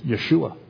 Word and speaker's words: Yeshua: So we pronounce it in the Latin form Joshua, but in the Hebrew it's Yeshua.